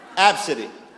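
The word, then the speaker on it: Abcde: Absidy.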